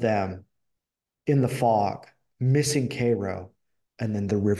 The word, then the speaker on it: Cairo: And they forge this friendship, and they are trying to get Jim to Cairo, Illinois. I mean, it's spelled like Cairo, but you know how we are.